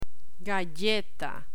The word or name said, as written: galleta